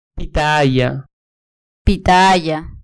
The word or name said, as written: pitahaya